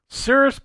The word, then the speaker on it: Cirrus: C-I-R-R-U-S. – Oh, like the clouds.